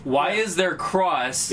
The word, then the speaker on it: cross: PlayStation has announced officially that we've been pronouncing the name of the button wrong since PS1's inception. It is not the X button. – It's cross. – It is the cross button.